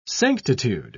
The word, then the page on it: sanctitude: British English